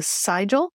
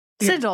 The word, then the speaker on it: sigil: So the other term that was new to me, and I pronounced it wrong as well. But I then I asked you how this was pronounced. Is a sigil? Sigil. Sigil. I see I did it the wrong way. Sigil.